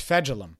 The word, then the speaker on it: Fejedelem: And Clayton Fegelum, I always say it wrong. Fegelum. It's Fegelum.